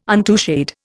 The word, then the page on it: untouched: Indian